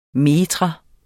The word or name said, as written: metrum